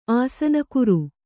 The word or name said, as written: ಆಸನಕುರು